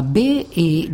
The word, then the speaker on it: bet: C'est le cas de la lettre bet, qui se prononce B avec ce qu'on appelle un point d'Agesh situé au sein même du caractère. Et lorsque on nommait ce point, eh bien la lettre se prononce V.